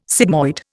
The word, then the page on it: sigmoid: Indian